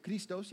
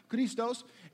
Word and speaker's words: Christos: That's how you have to say it, Christos. You have to roll your R's. It's really fun. Christos, and that's the name of Christ.